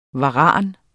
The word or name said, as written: varan